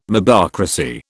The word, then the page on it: mobocracy: British English